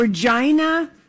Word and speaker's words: Regina: Well, and no, Regina is pronounced, rhymes with vagina.